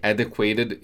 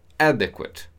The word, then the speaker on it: adequate: Be careful with the pronunciation. Adequate. Adequate. So the emphasis is in the first syllable, in a, adequate.